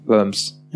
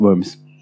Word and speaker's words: Worms: Worms. – Ja. Ja, mach weiter. D -d -d es heißt Worms. Ja. Es war nur eine Aussprachekorrektur.